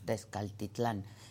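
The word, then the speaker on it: Texcaltitlán: se trató en la mañanera fue el de Tezcatlitlán, en el, ¿lo dije bien? Tezcaltitlán, perdón, Tezcaltitlán, en el Estado de México.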